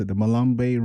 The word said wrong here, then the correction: Madiba: Rhythm? – Oh, Madiba Rhythm.